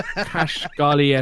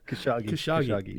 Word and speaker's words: Khashoggi: That, that, that could, ironically, that ties into his fifth choice that a Saudi show goes wrong with Kashgali esque consequences. – Khashoggi.